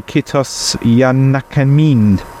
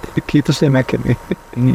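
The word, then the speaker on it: Kiitos ja näkemiin: Well, what I'd like to say, if I've got this right, I'd like to say kitos yanakamin. – Right, kitos yanakamin. – Is that right? Is that okay? Yeah, that's all right.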